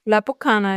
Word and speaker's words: La Bocana: La Bocana. Spricht man das vielleicht so aus? Man schreibt B-O-C-A-N-A. – La Bocana, ja.